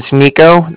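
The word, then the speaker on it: Niko: Bank of Cardiff, and that's spelled C-A-R-D-I-F-F, as in Frank. – Okay. – And my name's Nico, that's N-I-K-O.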